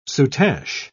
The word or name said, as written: soutache